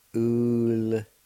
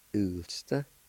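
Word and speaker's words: ulsda: In this word, the I with the L will be silent, and you can pronounce the L sound with the U like this. Ulsta. Ulsta. Ulsta.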